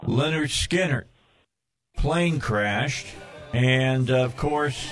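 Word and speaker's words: Lynyrd Skynyrd: The first album said pronounced Leonard Skinner on the front of it.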